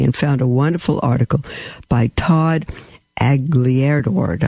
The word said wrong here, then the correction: Aglialoro: I can't pronounce it. Aglielord. No, Aglialoro has that. Todd, if you're listening, I apologize. Aglialoro, perhaps, on the Catholic Answers website.